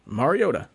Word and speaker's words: Mariota: Learn how to say the dude's name. I've heard Mariota, Mariota, Mariotti. I mean, how do you get this wrong? He'll say it for you. Mariota.